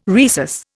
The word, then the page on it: rhesus: Indian